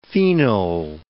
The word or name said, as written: phenol